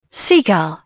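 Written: seagull

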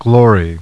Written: glory

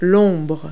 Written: shadow

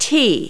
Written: t

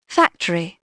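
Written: factory

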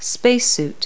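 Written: space suit

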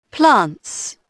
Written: plants

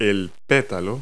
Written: petal

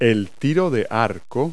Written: archery

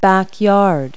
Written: backyard

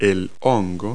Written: mushroom

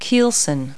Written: keelson

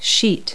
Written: sheet

